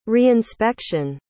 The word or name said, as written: reinspection